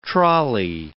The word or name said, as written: trolley